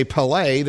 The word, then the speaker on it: Pelé: If in the Brazilian Portuguese they would say Pelé, then I would like to say it properly.